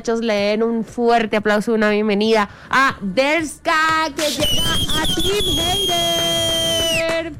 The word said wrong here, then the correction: Daresca: ¿Sí lo dije bien? – No. – No, lo dije mal, es que Daresca.